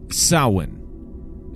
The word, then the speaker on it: Samhain: So yeah, Samhain. I think it's Samhain, is how it's actually like pronounced or whatever, because it means summer's end.